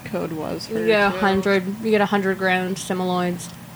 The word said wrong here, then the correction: Simoleons: 50 grand simoloids. Simoleons.